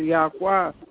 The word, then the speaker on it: Yahweh: If your phone is tell I say, uh, his name is YHWH, and we pronounce it Yahweh.